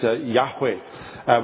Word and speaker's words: Yahweh: and now scholars believe that it ought to be pronounced Yahweh, which sounds in Hebrew like the verb to be, I am.